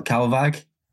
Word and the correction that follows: Kalvåg: Huh? Calvag. – Cal Cal Calvog. Calvog. Nice. K A L V A G. Kalvåg. Yeah, Kalvåg.